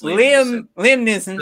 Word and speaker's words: Neeson: Neeson. It's Neeson. Yeah. There's no L.